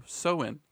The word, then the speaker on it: Samhain: Halloween's origins date back to the ancient Celtic festival of Samhain, pronounced oh Soin. now listen, you guys have to know this is spelled in English S A M H A I N, but is apparently pronounced S O W I N.